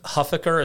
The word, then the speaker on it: Huffaker: Because Huffaker, is that how you say your last name? – Correct, yeah.